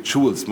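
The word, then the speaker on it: Choules: Nun ist mit Schul, so hieß das dann mit Claude Stanley Jules, muss man ihn aussprechen, nun ist mit Jules auch ein Teil deutscher Geschichte gestorben, titelte die Bild-Zeitung am 5.5.2011.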